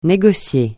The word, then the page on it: négocier: francais